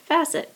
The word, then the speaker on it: Fassett: And he said the way to remember how to pronounce his name, although he says it makes no difference to him, is if you think of the words safe asset. So safe, Cafe, asset, facet. So it is Cafe Fassett.